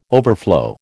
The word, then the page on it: overflow: American English